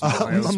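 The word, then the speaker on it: Mikkola: It's funny that you bring up, is it Mikolas? Is it Mikolas? Is that how you say it? Nico Mikolas? Something like that? – Mikola. – Mikola, yeah.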